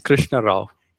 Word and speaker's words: Krishnarao: It's Krishnarao. – Krishnarao. All right, great. Thanks. Yes. Yep. Okay. – So it's Madhukar Krishnarao, or just call me Madhu.